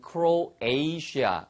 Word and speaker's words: Croatia: 크로아티아를 영어식 발음은 미국식 발음에서는 Croatia 이렇게 발음하시면 되겠어요.